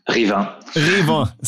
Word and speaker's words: Rivain: Rivain.